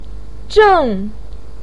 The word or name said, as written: zheng4